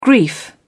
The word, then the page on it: grief: British English